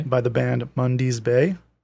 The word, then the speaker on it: Mundy's Bay: The band Mundy's Bay. So that's spelled MUNDY'S, mm-hmm. Mundy's Bay.